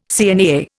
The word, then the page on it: CNAA: Indian